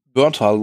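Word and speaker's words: Bernthal: Also das, ich glaube, das liegt einfach tatsächlich am, am Hauptdarsteller, John äh, Burntal. – Berntal, glaube ich, heißt, wird ja. der ausgesprochen.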